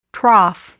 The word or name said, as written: trough